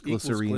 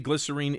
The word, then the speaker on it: glycerine: And then, yeah, we've got Fear plus me and plus you equals glycerine. – Equals glycerine. – I keep wanting to pronounce it glycerin. – Mm. I think maybe glycerine is, in fact, the British pronunciation of I think it is.